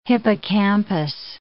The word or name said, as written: hippocampus